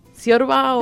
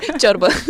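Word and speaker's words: ciorbă: ¿Ciorba o...? – Chorba.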